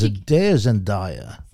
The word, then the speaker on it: Zendaya: It's pronounced Zendaya.